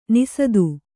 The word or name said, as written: ನಿಸದು